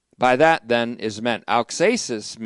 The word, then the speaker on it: auxesis: In rhetoric, it's called auxesis, A-U-X, long E-S-I-S, auxesis.